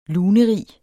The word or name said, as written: lunerig